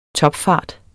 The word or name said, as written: topfart